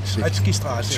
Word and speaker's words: Schretzkistraße: Da jeg gik herhen til Schretznikstraße, det er næsten Schretzkistraße. – Schretzkistraße, ja. Det er jo ikke til at udtale, det er jo slet ikke til at stave.